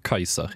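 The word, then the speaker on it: Caesar: Jeg leste at uh, ordet sæsar, faktisk eh, trolig uttales Kaiser, ja, egentlig.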